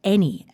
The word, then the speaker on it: any: The list is made up of really common but tricky words like any and Wednesday. All of them have something a little bit sneaky about their spelling, like the unusual pronunciation of the letter A in any or the silent D in Wednesday.